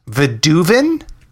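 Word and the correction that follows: Vadouvan: Oh, Vaduvan. – Vaduvan? – Yeah, that's how I would pronounce it.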